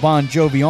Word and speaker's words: Bongiovi: They spell it J-O-N when his real name is actually spelled J-O-H-N. And he goes by John Bon Jovi with a B-O-N-J-O-V-I. The real spelling of his last name is like bong, like smoking a bong, B-O-N-G-I-O-V-I, but it has the same pronunciation. Bon Jovi with the G-I-O-V-I.